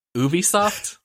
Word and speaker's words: Ubisoft: I'm gonna say Ubisoft? – Yeah, that's right. – Okay. – Darn it, I was really hoping you went with the Ubisoft and then I could pick on you.